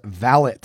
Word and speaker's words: valet: It's not a French word. So pronouncing the last syllable as A is incorrect. It should be sounded as valet.